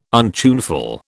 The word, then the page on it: untuneful: British English